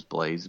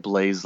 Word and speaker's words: Blais: And then my last name is Blaze, BLAIS. Sounds like Blaze, like a fire, but it's spelled IS on the end instead of ZE. I'm also on Instagram,